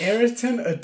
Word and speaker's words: Ayrton: It's A-Y-R-T-O-N.